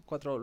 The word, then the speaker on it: 4o: Estuve hace poco en un seminario donde decían, bueno, es que ChatGPT 4O, es 4O, lo he dicho bien, ¿no? – Sí, salió el otro día.